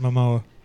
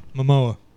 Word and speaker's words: Momoa: By the way, you pronounce it Momoa. – Sorry? – Momoa. – Oh, yeah? – Yeah. You said Momoa. – I said Jason Momoa. – Yeah, Momoa. – Momoa? – Yep.